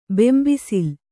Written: ಬೆಂಬಿಸಿಲ್

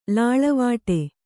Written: ಲೀಲೆವಡೆ

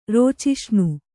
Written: ರೋಚಿಷ್ಣು

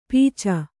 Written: ಪೀಚ

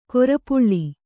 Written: ಕೃಪಾಳು

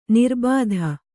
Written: ನಿರಾಬಾಧೆ

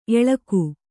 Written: ಏಳಕ್ಕಿ

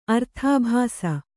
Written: ಅರ್ಥಾಭಾಸ

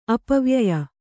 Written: ಅಪವ್ಯಯ

